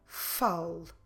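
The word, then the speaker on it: fale: Of course, that when we pronounce it, we will naturally drop it. It will just sound like fal.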